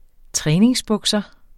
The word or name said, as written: træningsbukser